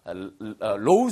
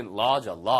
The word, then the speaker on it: law: 이걸 법대라고 할때 우리가 low school 그러면 낮은 이란 뜻이에요. 그러니까 그게 아니고 law school. 이게 law죠. law.